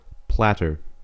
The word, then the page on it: platter: American English